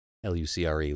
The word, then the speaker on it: lucre: And yeah, it's, it's pronounced lucre. L U C R E, lucre.